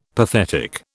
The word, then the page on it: pathetic: British English